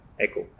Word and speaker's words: EKO: You pronounced EKO or Echo? – Echo. It is really, we wanted Echo to Echo, ECHO. And I have a close friend, he's a film director. And he gave me the suggestion that I shouldn't spell it as ECHO. So we went ahead and stylized it as EKO. It's Echo, and we really wanted to echo across the country.